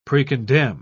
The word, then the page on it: precondemn: British English